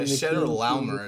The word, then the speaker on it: Chedorlaomer: Yeah, Chedorlaomer is how I pronounce it.